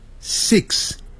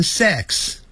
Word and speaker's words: six: 那个 v 是有声音的，不过真正美国人在念这个时候，他是念成没有声音的 five five，要把 v 念出来的时候，他停住了 five five，但他不会念成 five five five five，六 s i x six，一定。 it it 二三四 it 短的元音 six six，很多人会念成 sex， sex 是性，性别那个性，好 sex 那是 s e x，所以六啊要念成 six six， seven 七 s e v e n seven ven，那个 v。